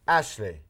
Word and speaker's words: Ashley: Ashley.